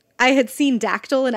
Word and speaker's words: Dactyl: So it's D-A-C-T-Y-L. Hill. – Like pterodactyl.